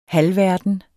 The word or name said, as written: halvverden